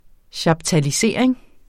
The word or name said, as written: chaptalisering